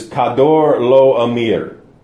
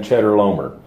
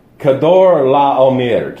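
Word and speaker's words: Chedorlaomer: I'm going to try to pronounce his name the way it's supposed to be. It's Kedorlaomer. Now, all my life I've heard preachers get up and call this man Cheddar Lomer. That's fine, but Kedorlaomer, king of Elam.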